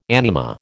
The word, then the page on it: anima: American English